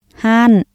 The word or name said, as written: ຮ້ານ